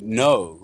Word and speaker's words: know: Which is actually, we have a remnant of that in our modern English with the word know. If anybody's ever wondered why we don't pronounce how on earth the K got slapped onto the word know, it's because it comes from the Greek.